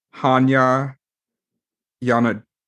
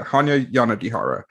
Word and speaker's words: Hanya Yanagihara: The book A Little Life, and I believe the author's name is pronounced Hanya Yanagihara. Yeah, Hanya Yanagihara. I looked it up 1,000 times how to pronounce it, and I'm sorry if I got it wrong still.